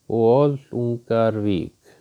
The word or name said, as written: Bolungarvík